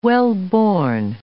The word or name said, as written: wellborn